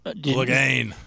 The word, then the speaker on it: Clegane: Clegane.